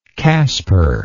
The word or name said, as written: caspar